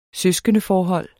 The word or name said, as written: søskendeforhold